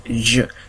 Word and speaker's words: je: If you listen to how I'm pronouncing, it's je. Sort of like you cut off the E. – Okay. – Before you finish the J-E. Because if it was English, you'd say je.